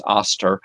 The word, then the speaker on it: Ishtar: That's how you pronounce Ishtar, is Aster.